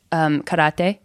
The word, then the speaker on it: karate: So the correct pronunciation is karate. You know, with my white girl accent, it's karate. If I say karate, people know what I'm talking about.